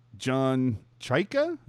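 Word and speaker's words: John Chayka: President of Hockey Ops and General Manager John Chyka, am I pronouncing that correctly? – Yes, you are.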